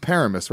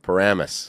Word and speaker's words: Paramus: You know it's not pronounced Paramus, right? – Paramus. – There you go.